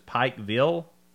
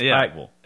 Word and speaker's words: Pikeville: And somebody says Pikeville. If they pronounce both the I and the vill very clearly, it's not right. It's Pikeville.